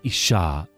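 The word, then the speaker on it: Isha (אִשָּׁה): The man in Hebrew is Ish, I-S-H, phonetically. Female is Isha, I-S-H-A.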